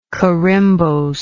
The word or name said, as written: corymbose